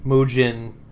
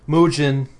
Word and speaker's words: Mugen: And Mugen as well. Because in the next post, there's more of a reveal. Mugen meaning infinity. Or Mugen. – It's a hard G, not a soft G.